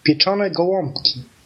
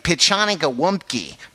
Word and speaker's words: pieczone gołąbki: We pronounce it as pieczone gołąbki. Repeat after me. Pieczone gołąbki.